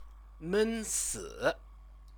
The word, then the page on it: mensi: Chinese